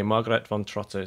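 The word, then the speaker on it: Margarethe von Trotta: but I believe it's Margerethe von Trotta. Is that right? People are nodding. Okay, Margerethe von Trotta is the director of this film called Vision, but we're going to hear a little bit more about that in a moment.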